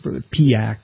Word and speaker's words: Piëch: But at one time, they had a CEO, and his name was Ferdinand Piëch. I mispronounced that. It's Piak, a German name.